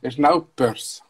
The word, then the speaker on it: Snowpiercer: Y el último estreno que traigo, la última recomendación que traigo de Netflix es esta que vamos a ver ahora, que es Snowpiercer. Tío, ayer me salió mejor la pronunciación, pero. – Es verdad que ya te felicité. – Sí, ¿eh? Snowpiercer, ¿ves? A lo mejor. – Ahora sí.